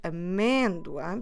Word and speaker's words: amêndoa: And this word amêndoa, because we're stressing this syllable me, it takes that accent on the E, or in Portuguese on the E letter.